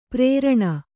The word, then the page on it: ಪುರ್ರನೆ: purrane